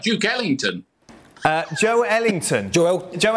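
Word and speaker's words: Joelinton: Yeah, I'm not a pedant when it comes to pronunciations, but literally at the top of my, my list here, it's our Brazilian uh, researcher, and he wrote Joe Linton is pronounced Joe Ellington. It's not pronounced Joe Linton.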